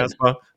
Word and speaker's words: Casbah: Did I say that right? Like the song? – You did. Casbah? – Okay, good, good.